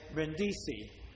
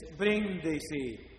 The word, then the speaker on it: Brindisi: because Americans say Brindisi. Wrong. It's Brindisi. The accent is in the first syllable.